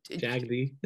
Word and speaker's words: Jagde: We need to clarify how we say your last name, because it is always, people say to me, oh, Brian Jage? – Jagde.